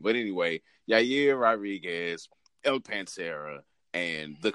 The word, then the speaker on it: Yair: And it has someone in it whose name rhymes with year, in Yair.